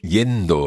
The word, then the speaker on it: yendo: Okay, you'll hear that a little like a j sound almost. Yendo, yendo. And that's what we hear when we're looking at double L's and Y's in Spanish.